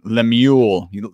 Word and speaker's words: Lemuel: Lemuel, like Samuel, except Lemuel.